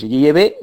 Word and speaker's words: Yahvé: Es un nombre propio, tiene un nombre propio que es Yahvé, esa es la auténtica pronunciación, pero un hijo siempre a su padre le llama papá, le llama padre, nunca le llama por su nombre propio.